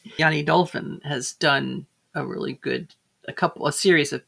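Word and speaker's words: Jenny: Yanni, which is spelled J-E-N-N-Y. J-E-N-N-Y. And the last name is D-O-L-F-E-N.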